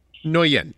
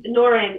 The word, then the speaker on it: Nguyen: Nguyen. – Nguyen. – Nguyen. It's harder to pronounce it if all your tongue is told R. A lot of people say Nguyen in English, so.